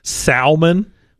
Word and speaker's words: salmon: Sal Freely. That's Sal. And you go, what's up, Mon? That's Mon. Salmon. – Salmon. – Salmon? – Salmon. – You don't pronounce the L? – Mm-mm.